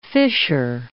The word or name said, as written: fissure